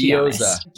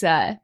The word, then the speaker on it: gyoza: Gyoza. – Be honest. Gyoza.